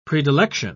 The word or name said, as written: predilection